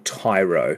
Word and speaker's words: Tiaro: And so, for example, there was a sign, I don't know if it's still there anymore, but there's a little town called Tyro, but it's spelt T-I-A-R-O.